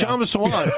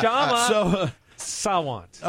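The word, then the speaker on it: Kshama Sawant: Kashama Sawant. I have been raked over the coals for mispronouncing that in Seattle. The K is silent. – We're not in Seattle. Yeah, it's Shama Sawant.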